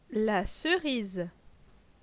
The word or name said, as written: La Cerise